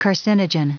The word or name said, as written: carcinogen